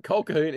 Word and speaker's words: Colquhoun: It's not Colquhoun, it's Colquhoun. – Colquhoun. – Colquhoun. – It's spelt Colquhoun. – I did a TV series with a friend of mine who's a Colquhoun, and it's Colquhoun. – Yeah, my lawyer's name is Colquhoun. That's on our correct way there.